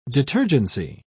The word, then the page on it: detergency: British English